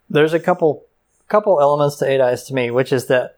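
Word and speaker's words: 8 Eyes: It's actually pronounced 8-E's.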